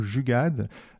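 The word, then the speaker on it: jugaad: C'est un mot, alors je ne sais pas si je le prononce bien, vous me corrigerez, c'est le mot jugad. c'est bien ça. de quoi s'agit-il alors oui, c'est ce qu'on pourrait traduire par de l'ingénierie frugale.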